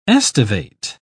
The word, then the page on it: aestivate: English; British English